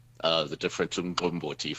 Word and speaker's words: umqombothi: I'm not going to pronounce umqombothi. – Umqombothi.